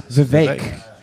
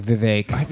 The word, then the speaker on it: Vivek: I thought it was Vivek, but then apparently he says it rhymes with cake. – Vivek. – Yeah, Vivek. Because someone talked shit about him on Twitter, and he goes, it rhymes with cake. So it's like Vivek.